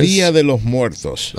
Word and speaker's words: Día de los Muertos: Día de los Muertos.